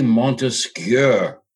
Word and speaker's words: Montesquieu: That's how we all pronounce it, but it's really Montesquieu, Montesquieu, which in French is cure, so it's Montesquieu.